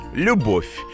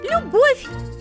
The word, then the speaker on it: любовь: В некоторых словах мы пишем букву «в», а произносим звук «ф» или «ф», если рядом есть мягкий знак. Например, в словах «морковь», «любовь» или «плов». В названии городов Саратов, Ростов.